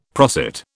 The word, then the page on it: prosit: British English